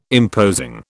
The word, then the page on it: imposing: British English